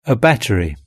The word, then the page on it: a battery: British English